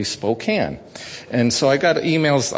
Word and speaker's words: Spokane: But it's Spokane, like a tin can, not like the cane of sugar.